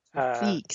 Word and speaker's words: Tofik: He has the same name, also Tofik, Tofik, sorry about that. I got the stress on the wrong syllable.